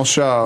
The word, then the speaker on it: Tso: The Search for General Tso. – Mm-hmm. – How do you pronounce it? Tso.